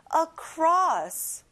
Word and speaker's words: across: 건너서, 건너편에 across, 친구들 cross가 아니라 across.